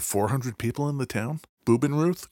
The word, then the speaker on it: Bubenreuth: So they go to nearby, called Bubenreuth. – I get the feeling you are pronouncing German like I pronounce Spanish. – That could very well be. – Bubenreuth.